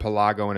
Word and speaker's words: Pelago: Outside of this debut album, how many times have you used the word Pelago in a sentence before? – I was writing it, and that's it. – It conveniently, he told me it conveniently rhymes with Chicago too.